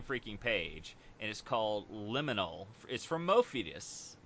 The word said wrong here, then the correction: Modiphius: Mof- uh, Modiphius. – Modiphius. More. I want. always want to say Morpheus. Yeah. It's Modiphius.